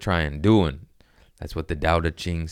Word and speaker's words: Tao Te Ching: No, it's a D sound. – It's a D, okay, Tao Te Ching.